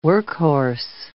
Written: workhorse

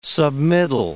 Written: submittal

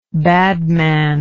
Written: badman

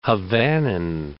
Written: Havanan

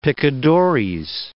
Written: picadores

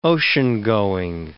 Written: oceangoing

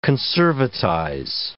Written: conservatize

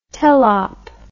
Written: telop

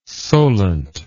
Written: solent

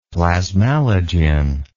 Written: plasmalogen

